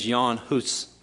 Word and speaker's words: Jan Hus: The way you pronounce his name is Jan Hus. But since that doesn't come naturally off a Mississippi native tongue, I'm just going to call him John Huss.